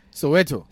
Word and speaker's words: Soweto: So You've come from, is it Swata? How do you pronounce the place you came from South Africa? – Soweto.